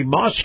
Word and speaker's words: Maus: Now, this portion of the program brought to you by Moss Family Automotive and Moss Family Automotive's Moss Acura dealership. Now, Moss is spelled M-A-U-S.